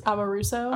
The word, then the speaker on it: Amoruso: how do you say her last name? – Amar- Amoroso. – Amoruso. Amoruso.